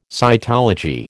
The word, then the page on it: cytology: American English